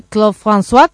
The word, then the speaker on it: Claude François: Mira, podemos nos poñer, verdade, en ese acento de Claude François, como son?